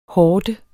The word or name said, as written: horde